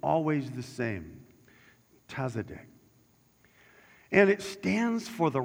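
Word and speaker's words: tzadik (צדיק): It's spelled T-Z-A-D-I-K, and it's pronounced like the word exotic. It's tzadik.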